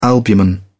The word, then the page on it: albumen: British English